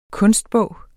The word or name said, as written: kunstbog